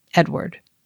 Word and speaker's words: Eadweard: Edward Muybridge also changed his own name one last time, reverting to the old Anglo-Saxon spelling that he recalled from his childhood, E-A-D-W-E-A-R-D, though he still signed his photos as Helios. It's spelled Edweird, but pronounced the same, Edward.